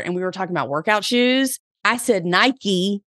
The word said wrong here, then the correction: Nike: And she was like, no, girl, it's Nike. And she's like, I live in the Pacific Northwest. That's where they're headquartered. That's not how you say it.